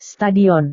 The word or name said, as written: stadion